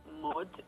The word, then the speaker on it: Maude: Então, eu tenho meu Instagram, que é arroba Maria Mode. Mode se pronuncia, né? Mas se escreve MAUD, então assim, Maude, né?